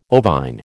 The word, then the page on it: ovine: American English